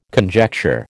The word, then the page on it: conjecture: American English